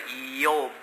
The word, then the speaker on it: Iob: Then let's read Job chapter 1. 我们看约伯记第一章哈。约伯记念着 Eob 比较好一点，I-O-B Eob，刚好跟我们的发音一样。啊啊，哎，Eob。对，不要念这 job 就不对，不要念这 job，变成职业的。